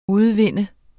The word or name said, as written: udvinde